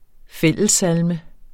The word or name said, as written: SALMER